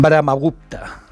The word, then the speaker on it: Brahmagupta: Brahmagupta lo he dicho bien, mira.